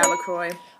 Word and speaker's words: LaCroix: Yeah, LaCroix.